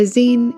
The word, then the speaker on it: zine: A zine is pronounced with a sharp I and sounds more like scream than fine.